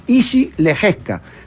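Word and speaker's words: Jiří Lehečka: Y se escribe Giri. – Giri, pero es... – J-I-R-I. Y suena como... – Izzy Lejeska, ahí está.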